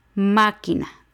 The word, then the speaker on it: máquina: Máquina, lo mismo, tres sílabas. La antepenúltima es ma y lleva el acento en máquina.